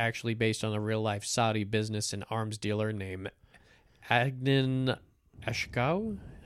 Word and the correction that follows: Khashoggi: How do you say that name? The third one down, Ashkadi? – Adnan Khashoggi. – Khashoggi.